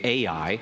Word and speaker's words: Ai: which we're hoping to prove is the ancient site of Ai, or what we may read and say Ai. It's spelled A-I in your Bibles, and you'll read about it here in Joshua chapter 7.